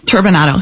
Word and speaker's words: turbinado: Uh, is it turbinado? Turbinado.